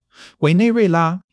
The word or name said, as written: 委内瑞拉